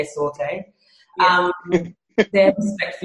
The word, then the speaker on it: sauté: So during um, jumps, which are called sautés, um, when I first started doing dance research, I kept saying saute, and all the dancers were like, no, no, no, no, it's saute.